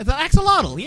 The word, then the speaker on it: axolotl: And number one is the, I'm not going to pronounce this correctly, the Axolotl. – The Axolotl, yeah.